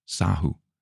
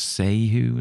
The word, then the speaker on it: Sahu: So I don't know if she's as Catherine or Kathy, but C-A-T-H-Y-S-A-H-U, Sahu. How else would you pronounce that? She said, oh, you've got it right. I don't know. I don't know how else you would pronounce Sehu or something.